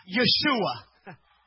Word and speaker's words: Yeshua: If you look up this word in the Hebrew text, salvation, it is pronounced as Yeshua.